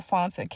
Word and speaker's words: Fanta: You can also follow me on my personal Instagram page at The Real Fonta K. If you would like, you can also follow me on Facebook at Fonta Canty. And my name is spelled F-A-N-T-A, last name K-A-N-T-E.